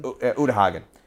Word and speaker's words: Uta Hagen: Is it Uta Hagen? – Uta Hagen. That's how you pronounce it.